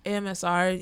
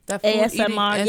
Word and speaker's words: ASMR: I'm like, why is that even even sometimes AMSR is too much for me. I think I said it wrong. – ASMR.